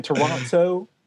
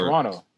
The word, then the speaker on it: Toronto: Listen, I was talking to my mom and she was just like giving me heat because I say Toronto and like I don't say Toronto or Toronto. – I can't say it like that. – Toronto. – But I've also been like, even like from like a child age, like I've been a stickler for like grammar. So like I'm pronouncing it as it should be.